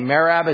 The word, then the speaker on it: Merab: M-E-R-A-B. Merab. Is that how we pronounce that? How would you pronounce it? Merab.